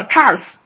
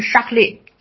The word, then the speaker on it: Charles: 他的儿子呢，也就是当时的王储啊，这个王储呢，我不知道为什么国内把它翻译成查理啊，查理，但实质上这个实际上这英文发音呢是叫 Charles。所以我我会管他叫 Charles，而这个这个这个词呢，这个名字在法语发音呢是 Charlie，就是查理啊。